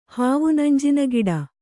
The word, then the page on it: ಹಾವುನಂಜಿನಗಿಡ: hāvunanjina giḍa